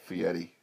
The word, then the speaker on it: Fieri: He pronounces it Fieri. – Fieri, Stone Cold Steve Austin, Kirk Gibson.